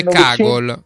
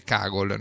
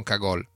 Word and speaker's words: Cagol: Comunque si dice Cagol. Comunque si dice Cagol perché lei è di Sopramonte, era di Sopramonte vicino Trento, e il cognome Cagol si legge Cagol, non Cagol.